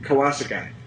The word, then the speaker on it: Kawasaki: We're not going to find a better fill-in than Kawasaki. – Is that how you pronounce it? – Yeah, that is how you pronounce it. Most people are getting it wrong these days, but that's in fact correct.